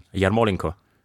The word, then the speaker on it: Yarmolenko: Yarmolenko. – That's how we should be saying it. – Yarmolenko. – Yarmolenko. It's the Yarmolenko. – You're stressing the second syllable as opposed to the third.